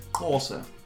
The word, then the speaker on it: Xhosa: The Wakandan language spoke in Captain America Civil War is Corsa. which is an official language of South Africa. Do you like how I actually said that properly as well? – Yeah. – Yeah, it's spelled with an X actually. – X-H-O-S-A.